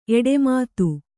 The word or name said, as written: ಎಡೆಮಾತು